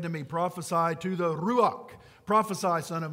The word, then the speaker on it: ruach: It's ruach. I love saying it because you have to roll your R, and I'm Irish and I can do that.